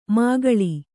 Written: ಮಾಕಾಳಿ

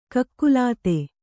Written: ಕಕಲಾತಿ

